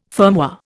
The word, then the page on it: firmware: Indian